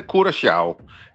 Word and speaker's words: Curaçao: Ja, hvis du må tage det helt korrekt, så siger det, hedder Curaçao. Det der C med den lille krølle under, det får sådan en sjav-lyd, men langt de fleste, de udtaler blot som et SC, så Curaçao.